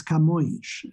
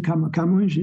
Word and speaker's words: Camões: No, it's Camões, very difficult to pronounce. So Luís de Camões is spelled Camoes, and it has this awful Portuguese diphthong, which is Oin, Camões. It's very difficult to pronounce, one of those awful things that the English language got rid of completely, which is why it's so popular.